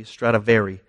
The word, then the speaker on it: Stradivari: Did I say that right? Stradivari, I got that.